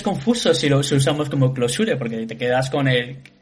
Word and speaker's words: Clojure: Se escribe Clojure, ¿no? Pero no se pronuncia así. Sería más a Closure.